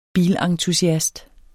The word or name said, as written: bilentusiast